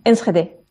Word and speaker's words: Enschede: ¿Cómo se pronuncia bien, por cierto? – Enschede. – Enschede.